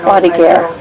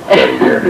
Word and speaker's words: croix de guerre: Croix de guerre. – Croix de guerre, but they, I think they pronounce it croix de guerre.